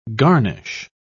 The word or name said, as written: garnish